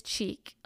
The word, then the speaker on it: Cheek: And then my maiden name is Cheek, like on your face, C-H-E-E-K. So yeah, and then Gifford is spelled G-I-F-F-O-R-D.